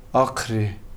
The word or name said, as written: aqri